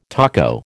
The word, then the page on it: taco: American English